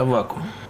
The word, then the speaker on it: Аввакум: Как все-таки правильно произносить? Я понимаю, что две нормы – Аввакум, как сейчас многие говорят, или Аввакум? – Древнерусская традиция – Аввакум.